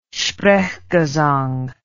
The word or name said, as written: sprechgesang